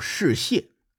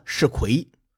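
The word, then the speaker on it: 士燮: 此前节目当中出现了一个人叫世谢，哎，我给读成了世奎。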